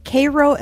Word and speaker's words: Cairo: And maybe my favorite for creativity is the Great Pyramids, the Sphinx, and desert landscapes titled with "Cairo, Illinois looks like something straight out of a movie." Cairo is how the locals pronounce it, but it's written the same as the slightly better known Cairo.